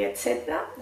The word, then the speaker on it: etcétera: No es muy, muy diferente, pero tenéis que comeros esa sílaba del final. Etcétera. No, etcétera, etcétera. Nos comemos ahí una sílaba.